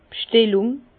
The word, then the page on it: d' B'schtellung: Alsatian; Bas-Rhin; Herrlisheim